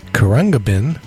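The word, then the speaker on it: Khruangbin: And we had Texas Sun by Kurangabin. Apparently that's how you pronounce the name of that band.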